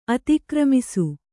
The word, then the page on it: ಅತಿಕ್ರಮಿಸು: atikramisu